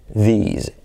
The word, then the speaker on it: these: Aunque no es eso tan relevante, sino aquí lo más relevante es hacer la diferencia entre this, E, and these, I, ¿vale? Pero que sepas también que uno es con S, this, y otro es con S sonora, z, these.